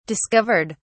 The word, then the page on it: discovered: British English